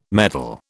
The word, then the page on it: medal: British English